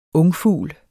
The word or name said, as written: ungfugl